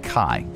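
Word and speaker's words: Kai (χ): It's pronounced like Kai, K-A-I, which is the abbreviation for Christ in Greek. The suffix mass is from the Latin-derived Old English word for mass. All right. M-A-S, Latin-derived Old English word for mass, as in a mass, like a celebration.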